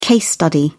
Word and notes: case study: British English

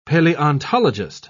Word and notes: paleontologist: British English

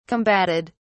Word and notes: combatted: British English

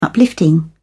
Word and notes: uplifting: British English

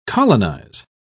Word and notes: colonise: British English

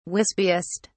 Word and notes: wispiest: British English

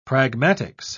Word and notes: pragmatics: British English

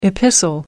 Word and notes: epistle: American English

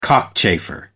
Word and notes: cockchafer: American English

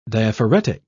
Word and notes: diaphoretic: British English

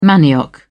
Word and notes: manioc: British English

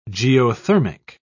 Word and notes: geothermic: British English

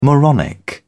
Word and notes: moronic: British English

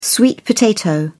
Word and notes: sweet potato: British English

